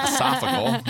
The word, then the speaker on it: esophageal: And I guess it manifests itself in I had a esophageal problems, which I was incorrectly pronouncing for a long time. – Esophageal. – I always thought it was esophageal. – Yeah. We were wrong.